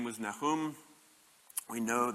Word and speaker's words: Nahum: Who is Nahum? And that's the Hebrew pronunciation, by the way, pronunciation, by the way, and I just like the way it sounds.